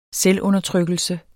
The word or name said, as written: selvundertrykkelse